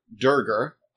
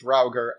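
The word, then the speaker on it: Duergar: a predominantly dark dwarf or Durgar. I will occasionally pronounce it Draugr, and I'm sorry. But Durgar. Durgar sounds like I'm mispronouncing it, is the thing.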